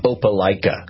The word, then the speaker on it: Opelika: They're the ones that would come back and say, hey, it's pronounced Opelika, you know, Alabama. And, is it Opelika?